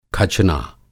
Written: खचना

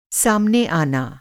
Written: सामने

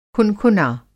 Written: खुनखुना

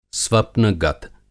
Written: स्वप्नगत

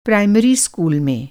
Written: प्राइमरी

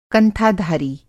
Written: कंथाधारी

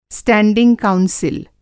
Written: स्टैंडिंग काउंसिल

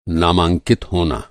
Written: नामांकित

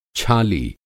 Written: छाली

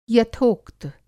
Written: यथोक्त

